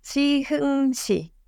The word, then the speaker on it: Siheung-si: I always say Siheung-Si, and yes. – How do you say it in Korean? – That's Siheung-Si. – That sounds way better.